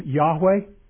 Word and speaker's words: Yahweh: It's the name Yahweh, or it used to be pronounced Jehovah, a German pronunciation.